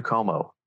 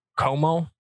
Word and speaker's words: Cuomo: Not gonna lie, like Cuomo. – Andrew Cuomo. – Cuomo.